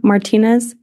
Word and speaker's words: Martinez: Yes, Tara Martinez, M-A-R-T-I-N-E-Z.